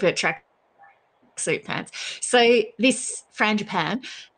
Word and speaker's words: frangipane: Frangipane? Frangipani? – Frangipan. Frangipan.